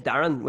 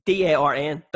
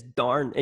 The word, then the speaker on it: Darren: E-R-N? – D-A-R-R-E-N is the proper spelling, yeah, but the pronunciation is Darren. In Newry, it's one syllable. I'm like, lads, come on. Give me the extra syllable.